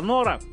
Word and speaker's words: Нора: Вот, только что у нас отзвучала Нора. Вот, Аня нам написала ударение на «о», так что я все правильно прочитал.